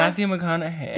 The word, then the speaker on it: McConaughey: Matthew McConaughey.